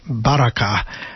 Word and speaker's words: Baraka: In both cases, there's that word Barack, Baraka, or as it's more properly pronounced, Baraka.